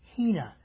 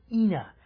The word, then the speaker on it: hina: And the purpose is introduced by a single little word that Peter uses called hina or ina. It's spelled H-I-N-A.